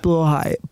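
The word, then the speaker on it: Blåhaj: I mean, every trans person, really, it's considered a rite of passage for the most part to own a blahaj. Or blow high, depending on how you want to pronounce that one. – It is pronounced blow high, but I think it's controversial because it's trans girls who have blow high.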